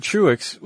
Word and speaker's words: Truax: I think it's Truax. – Truax, not yeah. – Tro? – Yeah, I think I remember the documentary. It was Robert Truax. – Okay, so Robert Truax was the guy who actually designed and, I believe, built the rocket.